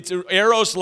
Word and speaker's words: eros: You got to roll the R a little bit, because it's eros love is romantic love.